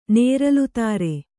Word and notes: ನೇರಲುತಾರೆ: nēralu tāre

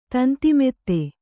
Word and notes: ತಂತಿಮೆತ್ತೆ: Kannada

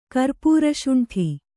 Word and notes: ಕರ್ಪುರಶುಂಠಿ: kannada

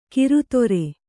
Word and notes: ಕರ್ದರಿ: kardari

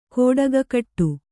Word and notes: ಗೌಟಿಕೆಗಟ್ಟು: gauṭikegaṭṭu

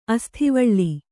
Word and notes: ಆಸುತೀವಳ: āsutīvaḷa

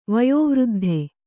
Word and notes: ವಯೋವೃದ್ಧ: Kannada

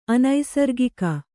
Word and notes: ಅನೈಸರ್ಗಿಕ: anaisargika

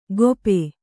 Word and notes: ಗೋಪೆ: Kannada

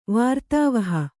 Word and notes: ವಾರ್ತಾವಹ: kannada

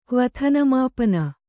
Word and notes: ಕ್ವಥನಮಾಪನ: kvathana māpana

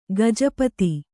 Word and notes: ಗಜಪತಿ: gaja pati; Kannada

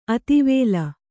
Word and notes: ಅತಿವೇಲ: Kannada

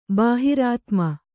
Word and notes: ಬಹಿರಾತ್ಮ: bahirātma

